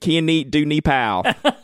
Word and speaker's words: Kyanite du Népal: Do you want the Southern version or the pretend French version? J. Herbon, 1978, Kianit du Népal.